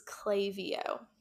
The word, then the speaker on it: Klaviyo: My number four choice is Klaviyo. It's spelled K-L-A-V-I-Y-O.